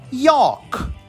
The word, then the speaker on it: yolk: Olha que lindo, gema em inglês, que a gente escreve Y-O-L-K. A gente não lê yolk, a gente lê York, York.